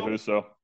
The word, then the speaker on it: Husso: but it's the the goalkeeper is it Husso? Billy Husso.